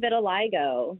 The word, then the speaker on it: vitiligo: Okay, segmental vitiligo.